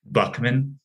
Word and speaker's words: Boeckmann: How do you say it? – Boeckmann. – Boeckmann. Oh, man. There's no you there.